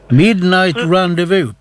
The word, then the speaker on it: Midnight Rendezvous: Ja, nu ska jag se om jag kan uttala detta, för det är Midnight Rendezvous med Fernandos. – Midnight Rendezvous, precis rätt uttalat och allting, med Fernandos.